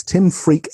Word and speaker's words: Tim Freke: It's Tim Freake, F-R-E-K-E.